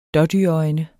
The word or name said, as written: dådyrøjne